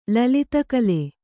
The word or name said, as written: ಲಲಿತಕಲೆ